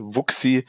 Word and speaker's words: Wuxi: Eine Stadt, die ich sehr mag, ist die Stadt Wuxi, wird geschrieben W-U-X-I, Wuxi, ausgesprochen Wuxi.